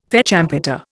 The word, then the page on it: fête champêtre: Indian